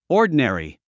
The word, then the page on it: ordinary: ɔ’ː r d ə nèri; オーディナリィ